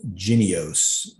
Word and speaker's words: Jynneos: I think it's pronounced Jynneos. Yeah, that's right. J-Y-N-N-E-O-S.